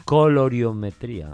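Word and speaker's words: colorimetría: Gracias por esa colorometría. ¿Lo he dicho bien? – Coloriometría.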